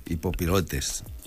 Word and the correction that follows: hipopilates: Hipopilates. – Pilates, això.